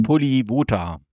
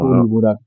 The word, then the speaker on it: Polivota: Da ist ja noch der A-Junior, der hat ja auch ein paar Spiele gemacht, hier Polita, oder wie ist der, wie wird das Spiel ausgesprochen? – Maximilian Polivota. – Genau. Polivota.